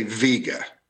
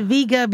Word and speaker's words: Vega: Dean is right if we live 3,000 years ago. – Oh, snap. He does have like a very old iPhone. – That's the classical pronunciation. – Okay. – The old pronunciation. – He's happy with that. He's making motions like, this is great. – Yeah. Most people today say Vega, okay, rather than Vega.